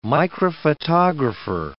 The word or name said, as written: microphotographer